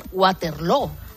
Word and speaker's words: Waterloo: Además, aquí lo pronuncian Waterloo.